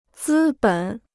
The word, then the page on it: 资本: zī běn